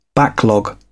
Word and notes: backlog: British English

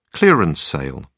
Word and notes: clearance sale: British English

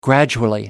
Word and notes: gradually: American English

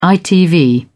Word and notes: itv: British English

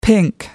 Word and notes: pink: American English

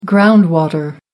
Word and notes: ground water: American English

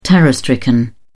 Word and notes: terror-stricken: British English

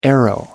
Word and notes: arrow: American English

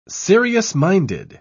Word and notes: serious-minded: British English

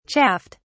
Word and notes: chaffed: British English

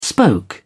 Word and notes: spoke: British English

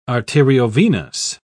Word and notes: arteriovenous: British English

